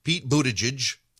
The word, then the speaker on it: Buttigieg: Pete Buttigieg, they told you to pronounce his name that way. Pete Buttigieg.